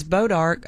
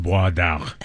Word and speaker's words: Bois d'Arc: B-A-U-S. – B-O-I-S, D, apostrophe, A-R-C. In French, it's pronounced Bois d'Arc. – Whatever. – Yeah. We call it Bodark because we're from Texas.